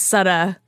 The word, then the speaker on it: Sutta: My name is Sutta, it rhymes with slut, so I was Jessica Slutta.